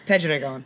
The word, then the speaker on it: Pedregon: how do you pronounce that? Pedregon? – I'm actually looking to you, international relationships. – Yeah, Cruz Pedregon.